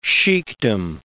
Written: sheikhdom

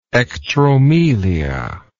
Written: ectromelia

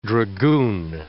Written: dragoon

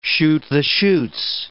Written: shoot-the-chutes

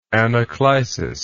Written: anaclisis

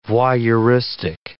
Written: voyeuristic

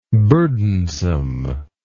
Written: burdensomeness

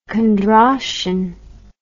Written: Kondrashin

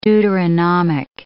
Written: Deuteronomic

